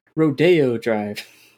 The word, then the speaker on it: Rodeo: It's pronounced Rodeo Drive.